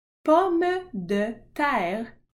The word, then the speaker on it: pomme de terre: Pomme de terre.